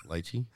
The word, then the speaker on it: lychee: Is it lychee or leche? – Leche. – Lychee? – Leche? – How do they spell it? – L-Y-C-H-E-E. – That's Jewish. It's lychee.